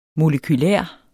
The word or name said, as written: molekyle